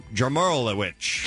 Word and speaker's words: Jarmolowicz: Jarmalik, what is W-I-C-Z pronounced? – Witch. – Witch? – Yeah. Yeah. – All right. Jarmolowicz. – Jamon. – Jarmolowicz. There we go.